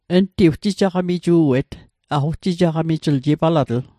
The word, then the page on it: ugjijaqamiju'et: Mi'gmaq